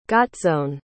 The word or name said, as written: gotzone